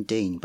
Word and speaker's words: Dene: My name is spelled D-E-N-E. Normally you spell your name Dean, but I'm just different because that's how I like to roll.